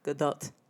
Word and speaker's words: Gadot: We were curious how to say her name, so I Googled it, and this was the first thing that popped up. – Gadot. – So now you know, because you heard it from her mouth.